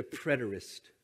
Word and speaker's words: preterist: The first is a preterist view. If you ever heard that term or read it and didn't know how to pronounce it, there you go. Preterist.